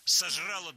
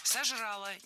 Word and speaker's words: сожрала: Сожрала два куска торта и что-нибудь такое. – Сожрала. Неверно. Правильно, сожрала. Ударение опять же переносим в конец слова.